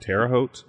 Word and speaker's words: Terre Haute: I'm probably saying that wrong. Yep. Terre Haute.